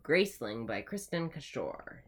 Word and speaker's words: Cashore: So the next book we're going to be reading is Graceling by Kristen Cashore. – Yes. – Accent on the second syllable. – Yes. – Cashore. – Wait. Yeah, it's Kristin. Like that kind of Kristen. And then Cashore.